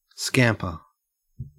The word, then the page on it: scamper: Australian English